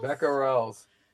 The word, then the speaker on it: becquerels: What is measured in, I don't know how to pronounce this, BECQUERELS? Becquerels? – Becquerels.